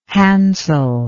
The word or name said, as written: Hansel